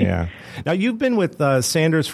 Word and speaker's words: Sanders: Is it Sanders or is it Saunders? – You know, it's phonetically pronounced Sanders, but even within the own Sanders family, some of them pronounce their name Sanders, some pronounce it Saunders. Really within the 1930s and 40s when we had a lot of European influence with the war, a lot of people changed the way they pronounced Sanders to Saunders. But Fred Sanders was the originator of the company, and it is correctly pronounced Sanders, but we do not correct anyone because obviously there's connections made to both pronunciations.